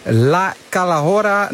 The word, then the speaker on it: La Calahorra: Het is een loodzware bergetappe van bijna 187 kilometer van, moet ik het in mijn beste Spaans zeggen, La Calahorra na Collado del Alguacil. Toch, wielercommentator Andries Lamer? – Ja, toch, als in dat ik eventjes die uitspraak beoordeel. Nou, helemaal goed, helemaal goed.